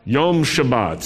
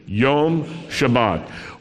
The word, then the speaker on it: Yom Shabbat: How do you say that in Hebrew, Sabbath day? Yom Shabbat. Say that with me. Yom Shabbat.